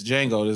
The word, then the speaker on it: Django: It's Django. – Nigga, stupid. The D is silent.